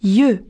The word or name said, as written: yeux